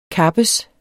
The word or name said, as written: kappes